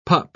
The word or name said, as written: pup